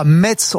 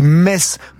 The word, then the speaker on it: Metz: En tant que spécialiste des accents et de la prononciation ici, je voudrais nous excuser tous, on dit pas Metz, on oublie le T, on dit Metz, comme les Messins,